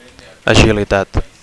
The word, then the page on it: agilitat: Catalan